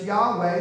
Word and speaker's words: Yahweh: I'm waiting for an English translation that will be bold enough to do like they did in the old days, not make it Jehovah. That was a decent rendering, but it's Yahweh, as best we know, the way to pronounce God's name.